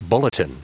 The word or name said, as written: bulletin